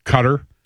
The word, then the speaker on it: Qatar: No, cutter. – Cutter's the way you're supposed to pronounce it. – Cutter, right? – Not the way I pronounce it.